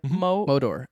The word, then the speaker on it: Modor: Motor. – Modor. – Am I pronouncing that right? – Modor. – M-O-D-O-R. – Like Mordor.